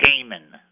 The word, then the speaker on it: Haman: We say Haman. That's how you say it.